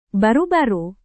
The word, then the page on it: baru-baru: indonesian